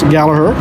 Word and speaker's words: Gallagher: It's spelled Gallagher, but it's pronounced Gallagher.